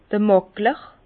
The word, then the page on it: d'r Màkler: Bas-Rhin; Herrlisheim